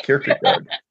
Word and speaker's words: Kierkegaard: Yeah, Kierkegaard.